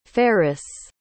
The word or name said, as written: faris